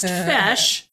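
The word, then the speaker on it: Feis: Fesh, which looks like Feist, but pronounced Fesh, is a traditional Gaelic arts and culture festival that almost commonly includes Irish dance competitions.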